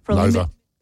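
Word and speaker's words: Loewe: You can upgrade your home entertainment with the premium Lowy TVs at Harvey Norman. – Lover. – Oh, is it Lover? – See, at the top how it says Lover. – Oh, yes. – Almost rhymes with sofa.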